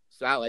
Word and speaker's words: Saleh: Saleh, I think I said it right now. – Yes.